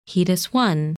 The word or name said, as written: Hoedus I